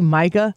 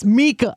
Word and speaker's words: Mika: and he called Mika Brzezinski Micah, and Bill Hemmer goes, did you just call her Micah? He goes, oh, I'm so sorry. They got Trump's name wrong and called him Hitler, but yes, Mika.